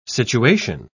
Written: situation

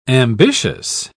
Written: ambitious

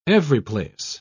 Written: everyplace

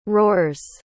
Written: roars

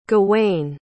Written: gawain

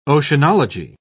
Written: oceanology

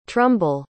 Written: trumbull

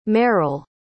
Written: meryll

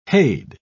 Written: hade

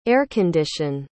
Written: air-condition

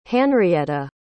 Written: hanrietta

